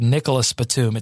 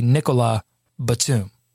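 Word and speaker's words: Nicolas Batum: I said Nicholas Batum. It's so easy, but it's actually Nikola Batum. The S is silent.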